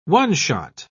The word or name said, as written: one-shot